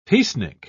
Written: peacenik

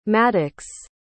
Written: mattox

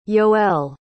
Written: yoel

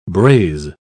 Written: braise

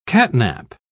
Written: catnap

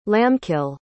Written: lambkill

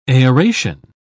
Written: aeration

